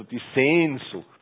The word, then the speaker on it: Sehnsucht: Deshalb würde ich die Betonung immer auf Sehen legen und dann Sucht, nicht? Also die Sehnsucht, so.